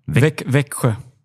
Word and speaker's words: Växjö: Wie spricht man das aus? – Växjö.